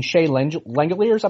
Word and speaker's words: Shea Langeliers: You're getting a lot of your mock drafts, and your mock draft came out fairly recently, and you had the Braves picking Shea Langeliers, I believe is how you pronounce his name. – Yep.